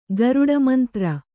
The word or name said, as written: ಗಾರುಡಮಂತ್ರ